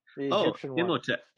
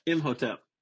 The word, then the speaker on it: Imhotep: Imhotep. Imhotep.